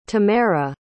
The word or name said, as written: tamera